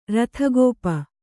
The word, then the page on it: ರಥಗೋಪ: Kannada